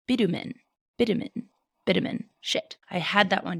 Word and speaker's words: bitumen: Throughout history, there's a black tar-like substance called, and before I say it, you guys, I know what this sounds like, but I looked up the pronunciation, and it is pronounced bitumen.